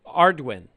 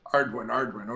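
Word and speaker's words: Ardoin: It is Ardwin. – Ardwin. Yeah, I asked him that. So it's just Ardwin.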